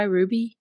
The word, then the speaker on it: RWBY: that show Ruby. Is that how you say it? R W B Y Ruby? Yes. Okay.